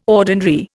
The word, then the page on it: ordinary: Indian